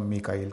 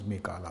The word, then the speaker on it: میکال: جبرائیل اور میکا، جس کو ہم میکائیل کہتے ہیں، قرآن مجید میں اس کا تلفظ میکال آیا ہے.